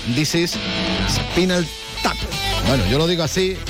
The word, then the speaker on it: Spinal Tap: Y mm, como digo yo, dices Spinal Tap. Así es como se pronuncia esto, como es... Eh, a ver. – Spinal Tap.